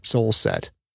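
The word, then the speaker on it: Sjoelset: She pronounces it Solset. How the hell does anyone get Solset out of that conflagration of consonants? I mean, it's S-J-O-E-L-S-E-T. For God's sakes, there's a J in there.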